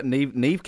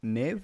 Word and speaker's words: Neve: Was that Neve Campbell? Is that how you say her name? – Yeah. – Neve? – Canadian.